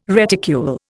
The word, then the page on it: reticule: Indian